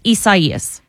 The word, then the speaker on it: Isaias: Now, I'm Southern, and so I'm saying Isaias when I'm talking a little too fast and a little too much, but it's Isaias.